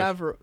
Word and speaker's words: Favreau: You say Favreau. Like, wait, how did you say that? – Favreau. – John Favreau. – I thought it was Favreau. Like, Favreau, not Favreau. – No, it's French.